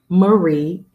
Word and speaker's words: Marie: Jesse spelled with an I, J-E-S-S-I-E, May, M-A-Y, Marie, M-A-R-I-E.